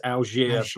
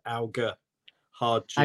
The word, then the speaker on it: Alger: I think it's French and I think it's Algier, but I'd sound terrible if I said it was that. So I just go with the very London-based Alger hard G.